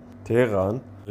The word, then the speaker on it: Teheran: Teheran, richtig ausgesprochen,